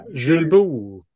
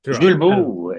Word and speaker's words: Julbo: Jules vad, vad hette nu då? Julbo. Julbo. Eh, ja. – inte Julbo, men liksom Nej. Eh, det var franskt eh, uttal på den där glasögonmärket som uppenbarligen många på tv har, de här värstingåkarna.